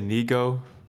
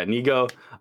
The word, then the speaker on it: Nigo: How do you pronounce the guy's name? I always forget. – I say Nigo. – Yeah, Nigo.